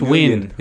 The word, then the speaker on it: Nguyen: It's pronounced Win. It's Win. – Win? Is it Win? – Win. Just Win.